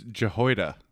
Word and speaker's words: Jehoiada: It's Jehoiada.